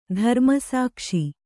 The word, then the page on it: ಧರ್ಮಸಾಕ್ಷಿ: Kannada